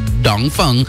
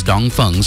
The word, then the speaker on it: Dongfeng: Now the Dongfeng box, I like a Lankan, like a true Lankan, calls Dongfeng Dongfeng, right? But the actual way to pronounce it is Dongfeng.